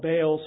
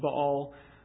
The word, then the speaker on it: Baal: Sometimes he's called Baal, sometimes it's pronounced Baal.